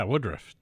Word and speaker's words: Woodruff: Yeah, Woodruff. 2 syllables. That's very hard.